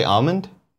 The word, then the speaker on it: almond: As long as you pronounce the L. Why? Some people say almond? Almond? Like without the L? – We're doing the housekeeping now.